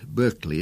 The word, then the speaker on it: Berkeley: By the way, it's pronounced Berkeley in America because that was the normal pronunciation of that and many similar words when the American colonies were founded.